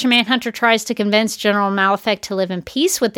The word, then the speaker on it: Malefa'ak: Malefic, I should mention that it is spelled MALEFA'AK, in case anyone was wondering.